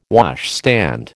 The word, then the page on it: washstand: American English